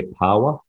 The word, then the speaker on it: paua: No, so abalone, we call it over here pawa, which is P-A-U-A, but it's probably pronounced, you pronounce it P-A-W-A, pawa.